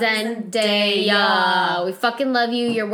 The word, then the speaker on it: Zendaya: I just pronounced her name wrong. Her name is Zendaya. – Zendaya. – Call her Zendaya.